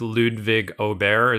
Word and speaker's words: Ludvig Åberg: It's with Ludvig Åberg.